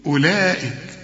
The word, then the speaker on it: أولئك: إلغي الحرف ده من النطق، قل لي أولئك،